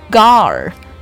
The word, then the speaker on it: guard: 然后 guard 这个单词不要读成 guard，一定要卷舌发一个的音，舌尖往后卷 guard。